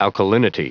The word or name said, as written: alkalinity